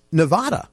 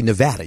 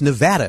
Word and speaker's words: Nevada: Now, you were Miss Nevada, right? Actually, Nevada. You were Miss Nevada, if we say it properly, and represented them in Miss America.